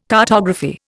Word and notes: cartography: Indian

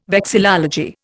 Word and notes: vexillology: Indian

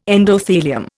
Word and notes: endothelium: Indian

